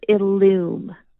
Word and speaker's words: Illum: Almost. Yep. We call it Illum, like illumination or illuminating.